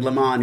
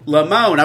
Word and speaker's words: Lamon: He goes, but how do you say the first name? And I said, Lamon. He said, no, Lamon.